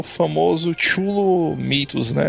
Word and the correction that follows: Cthulhu: É o famoso Cthulhu, né? Que, é. Na verdade, o nome Cthulhu, né, não, nem pode ser pronunciado pela garganta humana, né, mas é só na...